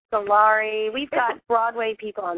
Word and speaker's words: Scolari: He says Scolari. He says, like, the end as in Larry.